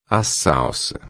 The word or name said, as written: salsa